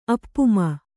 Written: ಅಪಮಾನ

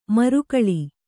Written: ಮರಗಾಳಿ

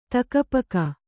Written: ಥಕಪಕ

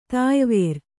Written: ತಾಯಿವೇರು

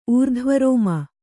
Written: ಊರ್ಧ್ವರೋಮ

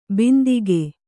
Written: ಬಂದೂಕು

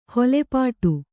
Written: ಹೊಲೆಪಾಟು

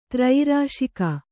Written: ತ್ರಯಿರಾಶಿಕ

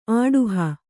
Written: ಅಡಹು

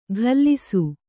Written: ಘಲ್ಲಿಸು

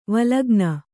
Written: ವಲ್ಗನೆ

